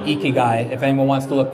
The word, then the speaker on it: Ikigai: So that's called Ikigai and it's spelled I-K-I-G-A-I, I believe.